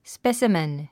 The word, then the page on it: specimen: spésəmən; スペェスィメン